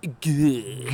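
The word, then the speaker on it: gueuze: And if you get a good guz that's been done properly, it's mind-blowing. So, how do you spell that? – G-E-U-E-Z-E. Good. I believe it's pronounced good.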